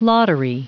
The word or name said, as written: lottery